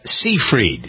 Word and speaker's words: Seyfried: Amanda Seyfried. Is that how you pronounce her name? – Yeah. Mm-hmm. – Seyfried? – Yep.